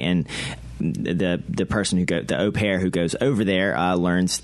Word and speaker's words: au pair: Number 11 on the list seems to be way out there, but it's an au pair, I believe is how you say it. – Yeah, host an au pair.